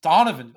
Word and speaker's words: Donovan: And I heard it the way you pronounced it, and my head exploded. – The Delco has a way of coming out. – You're from here. It's fine. I just had to ask you. Well, it's Donovan.